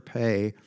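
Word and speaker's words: Pe: Pay, the P in pay, the Hebrew letter pay, sounds, you pronounce the P just like you would in peace, in the word peace.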